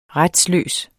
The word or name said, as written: retsløs